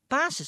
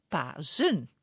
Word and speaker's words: Pasen: Want volgens de normale spellingregels moeten we niet Pasen zeggen, maar Pazen.